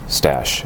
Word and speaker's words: Stash: It is, in fact, pronounced Stash, as in mustache.